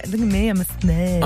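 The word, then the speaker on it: Ssaint: Kiara and Elle from Saint, and I say it like that because it's S-S-A-I-N-T.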